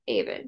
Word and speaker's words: Aven: Aven. – Yvonne? Aven? – Yeah. – Okay. With like, so like with a two eight, Aven. Okay. – Yeah, like even with an A, Aven.